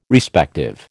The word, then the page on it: Respective: American English